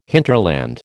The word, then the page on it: hinterland: American English